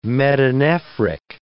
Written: metanephric

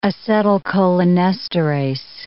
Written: acetylcholinesterase

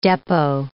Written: depot

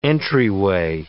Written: entryway

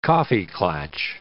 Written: coffee klatch

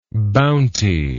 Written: bountyless

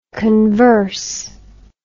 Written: converser

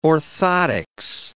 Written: orthotics

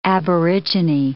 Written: aborigine

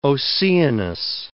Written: Oceanus

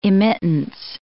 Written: immittance

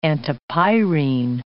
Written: antipyrine

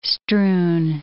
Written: strewn

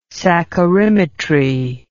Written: saccharimetry

